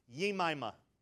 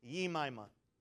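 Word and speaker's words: Jemima: Job called his first daughter, here's the proper pronunciation, Yemima. The J is silent, it sounds like a Y. Yemima.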